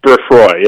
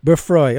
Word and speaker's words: Beffroi: Ed Simon is the author of America and Other Fictions and an editor for the British site, and I may mispronounce this, Beffroi, is that it, Ed? – I think it's Beffroi, yeah. – Beffroi, okay, I was doing the French on it.